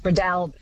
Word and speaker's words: Breadalbane: David in Tamworth says, I always understood it to be Breadalbane with the emphasis on the second syllable, the third almost not pronounced, which is such an Aussie way of doing it. Breadalbane, do you think, does that sound good? – Close, more A on the A, Jess. Bradalbin.